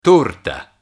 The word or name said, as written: torta